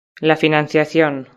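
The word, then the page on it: la financiacion: Spanish (Spain)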